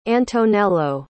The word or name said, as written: antonello